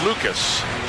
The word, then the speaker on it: Lukes: Lucas spells his name L-U-K-E-S. It is pronounced Lucas.